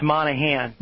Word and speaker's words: Monaghan: Well, everybody leaves the G in there. It's Monaghan.